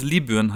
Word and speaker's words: Libyen: Aber das ist ja wie wenn man sagt, Also korrekt ausgesprochen würde es Libyen heißen.